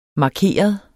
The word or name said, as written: markeret